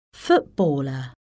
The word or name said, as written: footballer